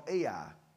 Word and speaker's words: Ai: The next city is a place called Ai. Ai, that's how you spell it, Ai.